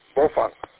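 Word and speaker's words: Baofeng: And I don't know if you guys realize, not that it's a big news or anything majorly to talk about, but everybody calls it Bofang, Bofang, Bofang. And it's actually pronounced Bofang.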